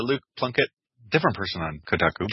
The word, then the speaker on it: Kotaku: So there's this article again on Kotaku, someplace. – Kotaku. – And I am place that my brain just refused to use the right pronunciation.